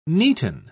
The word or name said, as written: neaten